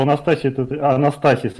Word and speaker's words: Анастасис: Анастасис, это правильное ударение, да? – Да, это правильное ударение. Еще хотел просто отметить, что Анастасис это не мое альтер-эго.